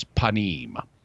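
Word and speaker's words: panim (פנים): So the word for face in Hebrew is panim. And you can hear that P sound on the front of the word.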